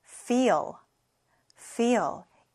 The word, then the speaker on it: feel: feel, feel. e가 두 개 붙으면 e가 엄청 길어져요. feel, 정확하게 발음에 주의합니다.